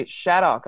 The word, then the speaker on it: Shaddock: I found out today, and then we also have uh, Nancy Shaddock.